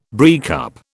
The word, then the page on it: breakup: British English